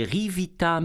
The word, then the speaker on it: Ryvita: Les Rivita multigrains, c'est bon ça. Les Rivita, Rivita ça s'écrit R-Y-V-I-T-A, OK? R-Y-V-I-T-A, l'autre, en troisième place.